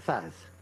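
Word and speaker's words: THAAD: With Thor, we would be out of Thads. Is that how he pronounced it? I don't know. – Thad missiles. – We got the Chad missiles. – No, they're Thad. T-H-A-D.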